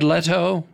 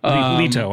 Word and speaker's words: Leto: And Jared Leto. – Leto, I believe it's pronounced.